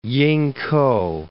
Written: Yingkou

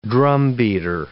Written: drumbeater

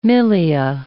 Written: milia

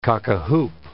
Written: cock-a-hoop

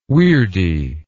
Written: weirdy

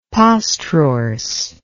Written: postrorse

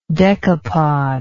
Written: decapodal

